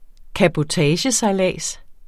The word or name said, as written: CABOTAGE